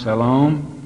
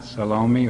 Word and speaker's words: Salome: Salome, pronounced Salome, not Salome or Salome.